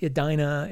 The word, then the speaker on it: Edena: But this this episode, I'm doing The World of Edina by Moebius, and that's spelled E-D-E-N-A, not E-D-I-N-A, not Edina. It's Edina. Think about you know, the Garden of Eden with an A after it.